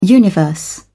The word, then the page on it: universe: British English